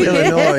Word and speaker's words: Illinois: Illinois, because we don't pronounce that S.